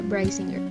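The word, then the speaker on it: Brisingr: Hasta donde yo investigué se pronuncia Brysinger pero pues ya depende de mucho de las traducciones y demás.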